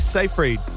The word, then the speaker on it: Seyfried: And actress Amanda Seyfried has revealed...